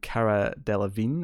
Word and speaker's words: Delevingne: And this is Amazon Prime's new original show, starring Orlando Bloom and Cara Delevingne, if I'm saying her name correctly, which I think I am.